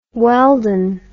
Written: weldon

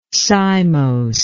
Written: cymose

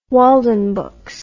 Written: waldenbooks

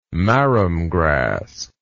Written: marram grass

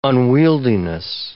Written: unwieldiness

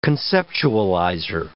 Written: conceptualizer

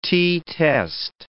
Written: t-test

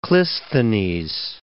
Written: Clisthenes

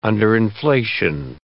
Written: underinflation

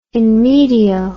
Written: in medio

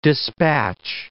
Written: dispatch

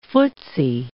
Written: footsie

